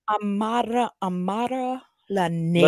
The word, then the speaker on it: Amara La Negra: Is that her name? If I'm saying it correctly? – Amara La Negra. – La Negra. La Negra. Right. – On it. – La Negra. I got it. Got to roll it.